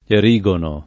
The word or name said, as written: Erigono